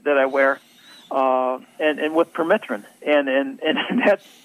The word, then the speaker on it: permethrin: permethrin. Is it? Am I saying it right? – Permethrin, yeah.